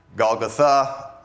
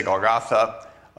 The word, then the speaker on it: Golgotha: Now, apparently, that is the more authentic way to pronounce that word. It's because very often words that you would see have the last syllable having the accent on them. So Golgotha, I'll try to say it that way. A lot of people just say Golgotha.